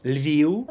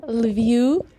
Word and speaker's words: Lviv: Just make sure there's no F at the end, but just a short U. The same with Kharkiv, Lviv. It's the same thing. It's the final V becomes a bit a short non-syllable forming U.